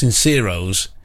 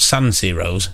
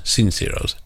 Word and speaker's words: Sinceros: This is the, I presume it's pronounced Sinceros. Not San Ciro's, Sinceros.